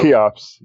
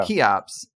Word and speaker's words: CHEOPS: CHEOPS, yeah. – CHEOPS. – Yeah, the key, that CH is Greek. It's a K sound.